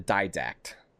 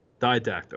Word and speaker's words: Didact: The name of that specific Forerunner, his name is the Didact. – Didact.